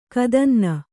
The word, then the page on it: ಕದನ್ನ: Kannada